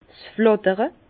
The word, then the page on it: 's Flàttere: Bas Rhin; Herrlisheim